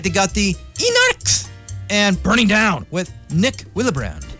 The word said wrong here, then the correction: Wilbrand: I'm sorry, I mispronounced that name. Wilbrand. Nick Wilbrand.